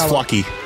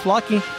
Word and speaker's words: Flocci: I hope it's pronounced Flochi. – I think it's Flocky. – You think it's Flocky? – Yeah, because look at his website at the bottom line. – Flocky. F-L-O-C-C-I.